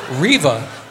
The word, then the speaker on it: Reva: You thought, you said Reva. Is spelled R-E-W-A, right? – R-E-V-A. In German, yes. – The W is a V.